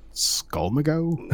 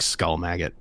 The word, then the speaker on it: Skulmagot: I don't really know what it is, but it's spelled Skulmagot. So I'm like, is it Skulmago? – I was gonna say Skulmago. – I think it's supposed to be like Skull Maggot, but yes.